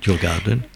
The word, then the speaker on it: Djurgården: L'automne, c'est pour moi la saison de l'île de Djurgården, je prononce bien. – Djurgården, Gordon, oui.